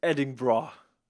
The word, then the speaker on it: Edinburgh: Want de start is een heel stuk noordelijker, of eigenlijk oostelijker, in, zoals jij het zegt, Edinburgh. – Ja, volgens mij is dat hoe je, hoe je het moet uitspreken. Eh, dat wat altijd een beetje gek is, omdat er eigenlijk e eh, Edinburgh staat, met een H erachter.